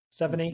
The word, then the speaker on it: Seveney: Seveney.